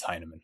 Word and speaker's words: Hinaman: If they've never met me, everyone, like really? 99%, unless they have a German background or some other reason to, you know, assume that it might be pronounced Heineman. But yeah, most people see it as Hinaman. But then once they meet me, then yeah, it's Heineman.